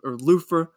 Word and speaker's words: Luther: I need to listen to more Luther, or Lufa, as you guys keep saying. – Thank you. That's how you say it.